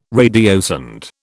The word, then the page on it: radiosonde: British English